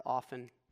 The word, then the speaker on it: often: And when we think about stripping away, oh, sorry, I was supposed to put the word often up here. Do you pronounce the T? Often, often. I feel like there's a big debate online about that.